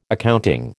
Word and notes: accounting: American English

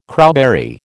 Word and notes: crowberry: American English

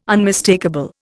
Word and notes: unmistakable: Indian

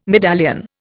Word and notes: medallion: Indian

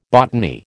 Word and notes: botany: American English

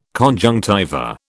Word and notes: conjunctiva: British English

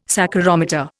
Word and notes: saccharometer: Indian

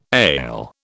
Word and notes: ail: British English

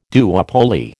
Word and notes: duopoly: American English

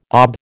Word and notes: ab: American English

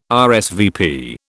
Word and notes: RSVP: British English

